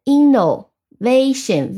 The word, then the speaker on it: innovation: 我们同时这个词的发音也跟我们刚刚介绍的这个规则特别像，重音在 vation，innovation。那我们拼一下 i。 N N O innovation V A T I O N I N N O V A T I O N innovation 啊，这个例句 innovation comes from inspiration，创新源于灵感。